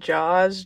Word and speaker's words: Jauz: My song is Goodies by, I think it's pronounced Jaws, J-A-U-Z. – J-A-U-Z? – Yep.